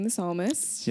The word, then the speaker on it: The Psalmist: Everyone go stream The Psalmist. – Yes. – Yes. – Yeah. It's a great. – Right now. – By the way, it's spelled P-S-A-L-M-I-S-T. – Yes. – Yeah, The Psalmist.